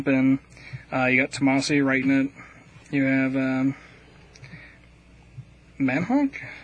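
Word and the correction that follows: Mahnke: Mankey, doing the artwork for it.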